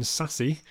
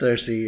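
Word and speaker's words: Sersi: She plays Cersei, or if you watch the first scene, Sassy. You seem to change the pronunciation partway through. – It's Cersei, yeah.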